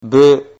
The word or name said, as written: bu